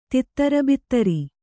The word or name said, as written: ತಿತ್ತರಬಿತ್ತರಿ